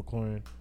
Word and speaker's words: McLaurin: AJ Brown, Terry McCullin, if that's how you say his name, McLaurin.